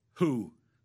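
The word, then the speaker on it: who (הוא): The Hebrew pronoun who. That's how you pronounce it.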